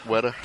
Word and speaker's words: Weta: And I even read that Weta, is that how you say it? – Weta.